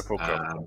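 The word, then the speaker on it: sepulchre: So I had to go and research one, which was sepulture, which was... – Oh, sepulcher, yeah. – Is that how you say it? – Sepulcher, yeah. Well, that's how I say it.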